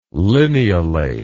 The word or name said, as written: lineolate